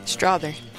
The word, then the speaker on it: Strother: Strother.